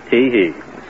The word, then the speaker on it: Teehee: The first initial is T, and my last name is spelled H-E-E, Teehee.